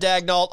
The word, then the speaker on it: Daigneault: So I was told a year ago that it was pronounced Daigneault, and then yesterday in the press conference, Presti said Daigneault. So I'm not really sure at this point what the correct pronunciation is. If Presti said Daigneault, I'm rolling with Daigneault.